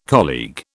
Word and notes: colleague: British English

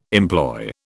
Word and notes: employ: British English

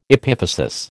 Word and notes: epiphysis: American English

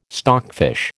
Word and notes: stockfish: American English

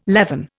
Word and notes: leaven: Indian

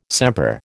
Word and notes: sempre: American English

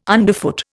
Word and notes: underfoot: Indian

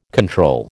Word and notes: control: American English

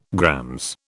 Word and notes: GR: British English

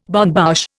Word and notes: bonne bouche: Indian

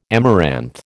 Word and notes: amaranth: American English

